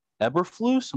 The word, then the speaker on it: Eberflus: Matt Eberflus, am I saying that right? – You correct.